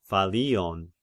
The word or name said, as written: Phallion